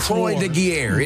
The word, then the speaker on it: Croix de Guerre: They awarded Henry Johnson the Croix de Guerre. I don't know how to say it. – The Croix de Guerre.